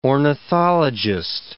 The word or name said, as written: ornithologist